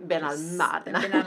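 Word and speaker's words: Benalmádena: Men eh, betoning då på det andra a i eh, ordet. Och namnet Ben al med betoning på det andra året sägs komma från arabiskan Ben al-Madena, alltså i tre stavelser och betyder gruvornas söner.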